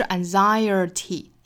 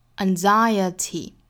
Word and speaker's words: anxiety: 所以不是 anxiety，应该是 anxiety 是一个 schwa sound 的发音，